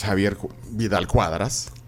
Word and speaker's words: Javier Vidal-Quadras: Bueno, yo, pues, yo lo sé, es Javier Vidal Cuadras.